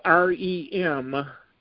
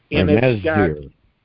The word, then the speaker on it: Remdesivir: It's spelled REMDESIVIR. – Remdesivir.